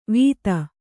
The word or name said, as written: ವಿತ್ತ